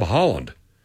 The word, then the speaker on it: Haaland: and his last name is spelled and pronounced exactly like Deb Haaland.